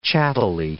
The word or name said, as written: chattily